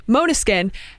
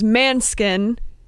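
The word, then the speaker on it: Måneskin: I they we were sitting next to each other in the crowd, and I was like, "How do you say it? Like, I want to say it right." They said "monoskin," and that is the Dutch way to say it, but the Italian way to say it is "manskin," right? Yeah, the other way.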